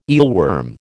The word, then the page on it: eelworm: American English